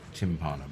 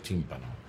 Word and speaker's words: timpano: So my family called it tympano, but actually it should be pronounced timpano. – Because it resembles the bass drum.